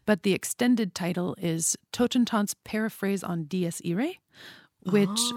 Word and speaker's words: Totentanz: It's called Totentanz.